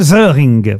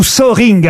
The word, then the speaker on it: Soehring: C'est son vrai nom, Hans-Jürgen Söhring. – Il vit en France Ou Söhring, hein, vous prononcez comme vous voulez. Ça s'écrit S-O-E-H-R-I-N-G.